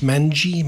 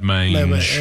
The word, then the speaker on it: mange: Is it pronounced mangy? – Wombat mange.